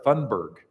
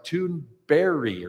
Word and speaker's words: Thunberg: I have pronounced it Greta Thunberg, and apparently that's not how you say it in Swedish. So Greta's right, but I think I got this right. I still have to not look at it to hear it, but I think it's like Thunberry or something like that. It's more like a soft J at the end that's more of an I.